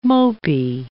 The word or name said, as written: mopey